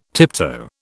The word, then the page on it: tiptoe: British English